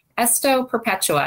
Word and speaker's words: Esto Perpetua: Esto Perpetua. Am I saying that right? – You are, yes.